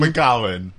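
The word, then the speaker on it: Mac Gabhann: McGowan. – But it's spelled with like H's and B's. Yeah. And it looks like MacGabbin. – It's spelled MacGabhan, but it's McGowan.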